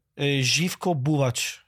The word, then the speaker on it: Živko Buvač: Živko Buvač.